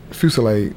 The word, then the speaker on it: Fusilade: Fusilade. I can't spell that one. F-U-S-I-L-A-D-E.